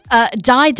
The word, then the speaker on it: Dai Dai: How am I pronouncing this? – Die. – But it's spelled D-A-I-D-A-I.